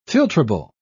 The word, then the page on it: filtrable: British English